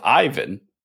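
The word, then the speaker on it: Aiven: Okay, so first of all, I'm lucky because the pronunciation of Ivan is the same in the Nordics and in Italy, making my life really easy.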